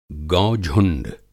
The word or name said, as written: गौ झुंड